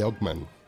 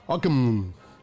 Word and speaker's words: Ockham: Hoy les voy a hablar de la navaja de Ockman. Ockham. dicen los clásicos que es como se debe pronunciar.